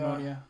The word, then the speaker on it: pneumonia: Pneumonia, do you pronounce it. Pneumonia. Pneumonia. Yeah.